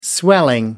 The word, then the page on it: swelling: American English